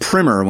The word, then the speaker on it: primer: primer is what you put in an engine to make it start, guys. When you're everybody in the Reddit who is trying to correct me. No, it's pronounced primer when it's a lesson, when it's a catching you up on something.